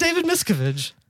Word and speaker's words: Miscavige: I think he pronounces it David Miscavige.